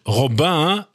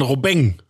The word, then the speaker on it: Robin: Und da sagt man zum Beispiel nicht Robin, sondern Robeng.